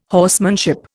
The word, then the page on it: horsemanship: Indian